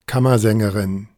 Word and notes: Kammersängerin: ˈkamɐˌzɛŋəʁɪn; German; female

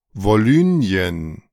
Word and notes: Wolynien: German

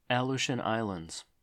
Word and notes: Aleutian Islands: əˈluːʃən; ə-LOO-shən; American English

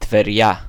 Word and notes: טבריה: Ṭəveryā; Hebrew

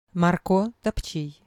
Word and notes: Марко Топчій: mɐrˈkɔ topˈtʃij; Ukrainian

